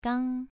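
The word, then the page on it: gang: tone 2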